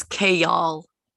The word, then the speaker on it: Kale: Also, I pronounce his name like the leaf, like the lettuce leaf, Kale.